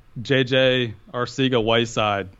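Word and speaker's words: Arcega-Whiteside: And then after that, I would say uh, JJ Arcega Whiteside. Uh, did I say his name right? – You yeah. Did.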